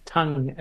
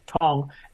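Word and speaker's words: Tongue: Admittedly, it wasn't their frontline attack, but they found a new young pace bowler, Josh Tong, or Tung as it's spelt, but they pronounce it Tong, and he looked to be a lively competitor.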